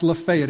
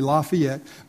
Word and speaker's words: Lafayette: Only in Alabama is it pronounced Lafayette. Everywhere else would be Lafayette.